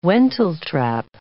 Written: wentletrap

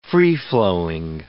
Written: free-flowing

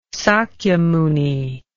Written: sakyamuni

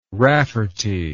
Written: rafferty

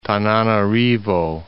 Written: Tananarivo

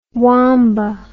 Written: Wamba

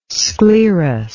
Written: sclerous

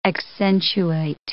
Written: accentuate

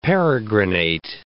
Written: peregrinate

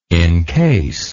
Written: incase

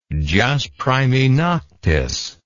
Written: jus primae noctis

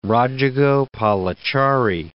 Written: Rajagopalachari

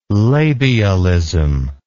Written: labialism

